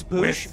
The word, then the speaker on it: Boosh: It's Boosh, with but it's pronounced like the old Bush commercials.